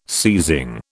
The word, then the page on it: seizing: British English